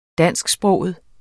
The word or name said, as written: dansksproget